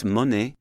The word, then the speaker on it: Monet: So it's Monet and it's Duga in French because there is no accent on that E.